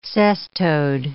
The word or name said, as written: cestode